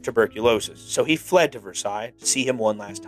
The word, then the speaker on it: Versailles: But for some reason, the city I live in Pittsburgh, there's a little area called North Versailles. They pronounce it Versailles. I'm telling you right now, it is Versailles. It makes me cringe every time I hear Versailles. Anyway, moving on.